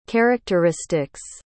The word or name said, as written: characteristics